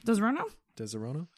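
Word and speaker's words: Disaronno: So, Desaronno? Am I saying that right? – Yes. – Desaronno? – Yeah.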